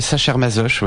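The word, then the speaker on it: Sacher-Masoch: Et donc c'est adapté d'un texte de Léopold von Sachermassor, c'est ça, Oui, il y a plusieurs prononciations, mais Sachermassoche, oui.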